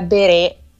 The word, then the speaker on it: Berest: Allora, il titolo è Nulla è nero, che quindi già qui è un titolo veramente molto di impatto, e l'autrice è Claire Beret, si scrive Berest, insomma, Ok.